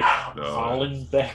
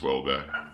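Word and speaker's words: Houellebecq: Wait, is that how you say his name? – Welbeck? – Yeah. – That's how you say it? – Yeah, how did you say it? – I was saying, like, Hollenbeck. – It's just Welbeck.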